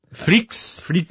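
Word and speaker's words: Freecss: From Hunter Hunter, Gon Freeks. Is that how you pronounce his surname? – Freaks. – I guess it's Freaks.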